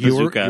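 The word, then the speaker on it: Bezucha: And I did look up how you spell his last name. It is Bazooka. – Yeah. – Like, you do pronounce it Bazooka.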